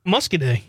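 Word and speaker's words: Muscoda: Gary from Madison says, the town in southwest Wisconsin is pronounced say it. Muscaday? – Muscaday. – Muscaday. – Muscaday, okay. Yep.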